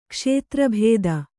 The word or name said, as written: ಕ್ಷೇತ್ರಭೇದ